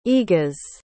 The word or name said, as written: egas